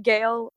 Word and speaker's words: Gayle: But Gale, like G-A-Y-L-E, because people spell it in different crazy ways.